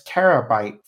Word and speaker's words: Pterabyte: And you recognize this as the villain known as Terabyte. For those playing at home, that is spelled P-T-E-R-A-B-Y-T-E.